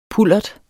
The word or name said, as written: pullert